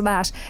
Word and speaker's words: Loesch: L-O-E-S-C-H. – Mm-hmm. But pronounced like Loesch. – Right, but it's pronounced Lash.